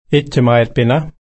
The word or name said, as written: ityamaiitpinna